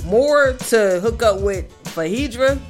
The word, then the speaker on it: Phaedra: I don't want to mess up her name. – Oh, that's right. Okay. – So I'm going to say his name, which is... – Fahedra, right? – Fahedra, there you go.